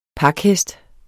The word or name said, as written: pakhest